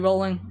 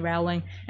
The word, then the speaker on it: Rowling: I know, Americans say it's JK Rowling. She says it's JK Rowling, so we're going with that pronunciation. In the UK, you often hear JK Rowling, and then here you hear Rowling, and she hates it.